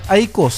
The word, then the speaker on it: AIK: Club, AIKO se llama el club. AIKO. AIK son las siglas. – AIKO. – Y se pronuncia AIKO. – AIKO. – Claro, todos dicen AIKO, pero es AIK, que son palabras en alemana, Idrottsklubben, significa club.